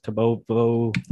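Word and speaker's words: tobamoviruses: Tobomoviruses.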